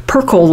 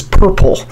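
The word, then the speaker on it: Perkl: In any case, it's a wonderful accident, and I just have to let my audience know that this Perkle light, and that's spelled P-E-R-K-L. I used to think it was, when I first heard about it, was purple, but no, it's P-E-R-K-L.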